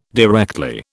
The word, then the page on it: directly: British English